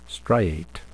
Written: striate

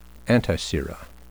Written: antisera

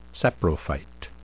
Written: saprophyte

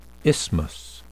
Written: isthmus

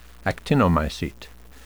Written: actinomycete

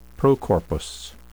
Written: procorpus

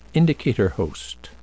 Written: indicator host

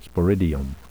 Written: sporidium

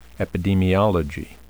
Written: epidemiology

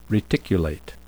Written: reticulate

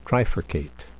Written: trifurcate

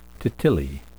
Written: titillae

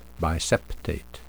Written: biseptate

